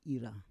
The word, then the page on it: ira: Roon